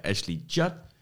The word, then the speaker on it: Ashley Judd: Our protagonist plays by Ashley Judd.